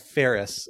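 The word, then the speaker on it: Feres: And I will note in the chat, Chris said that the Ferris doctrine is pronounced Ferris. So we're both a little bit off.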